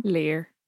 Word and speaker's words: Lir: There's the mystical chieftain Lir, L-I-R. How do we think we're pronouncing that? – Lir. – Lir.